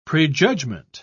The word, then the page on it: prejudgement: British English